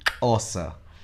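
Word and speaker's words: Xhosa: E se escreve X-H-O-S-A e se pronuncia assim mesmo, com esse clique. Ossa.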